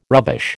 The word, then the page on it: rubbish: American English